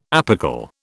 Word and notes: apical: British English

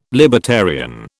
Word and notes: libertarian: British English

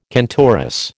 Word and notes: cantoris: American English

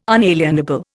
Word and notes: unalienable: Indian

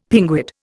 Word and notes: pinguid: Indian

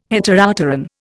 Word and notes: Heteropteran: Indian

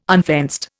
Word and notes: unfenced: Indian

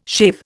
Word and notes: shave: Indian

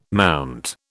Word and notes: MT: British English

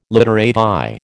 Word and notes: literati: American English

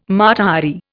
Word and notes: Mata Hari: Indian

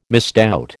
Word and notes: misdoubt: American English

